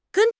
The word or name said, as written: gen1